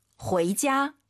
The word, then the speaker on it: 回家: 집에 가다, UI 사이에 에 넣어야 돼요. 후이자 해도 알아듣겠지만 정확한 발음. – 回家.